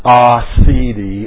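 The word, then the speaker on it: aseity: The doctrine of acedi is pronounced acedi.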